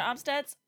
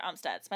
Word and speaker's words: Amstutz: I'm Sandra Omstedts. All of my social media handles are at Sandra Omstedts. My last name is spelled AMSTUTZ.